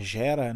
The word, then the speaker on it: Jetta: Você tem um Corolla, você tem um, um Jetta, que por sinal aqui é, é, é Gera, né? A pronúncia aqui eles, eles pronunciam Gera.